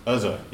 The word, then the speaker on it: Uzzah: Well, it's like those two guys that, was, I don't know how to pronounce his name, Uzziah. – Uzzah, yes.